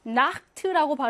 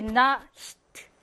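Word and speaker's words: Nacht: 여러분이 실제로 발음을 해봐. 나흐트라고 발음해야 편하지. 나흐트 다 이쪽에서 나야 편하지. 나히트 하면은 갑자기 나히 이렇게 뒤로 갔다 앞으로 갔다 이게 불편하잖아요. 그래서 나히트가 아니라 나흐트가 되는 거예요. 발음을 편하게 해주려고.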